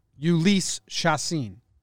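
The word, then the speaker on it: Chacín: No, because there's no way that we're pronouncing it correctly, and everyone's going to be mad at us. – No, we are. – Ulysses Chacin.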